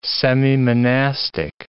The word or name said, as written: semimonastic